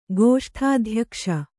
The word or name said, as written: ಗೋಷ್ಠಾಧ್ಯಕ್ಷ